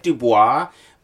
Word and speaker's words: Du Bois: Is it D-U-B-O-I-S? – It's spelled like Du Bois, but it's pronounced Du Bois for reasons.